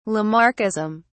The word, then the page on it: Lamarckism: British English